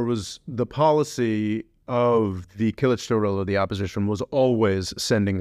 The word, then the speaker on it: Kılıçdaroğlu: Darulu? I'm pronouncing that wrong. – Kemal Kılıçdaroğlu is the opposition. – Kılıçdaroğlu.